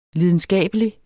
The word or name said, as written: lidenskabelig